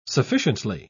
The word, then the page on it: sufficiently: British English